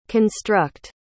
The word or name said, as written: construct